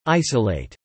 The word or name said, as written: isolate